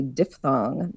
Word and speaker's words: diphthong: the other interesting thing about this is that the sound I is technically called a diphthong. That's D-I-P-H-T-H-O-N-G. – Often mispronounced as diphthong, which just sounds much cooler.